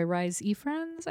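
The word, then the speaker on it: Rhys Ifans: and then portrayed by Rhys Ifrans, I believe. – Mm-hmm. – That's how you say his name.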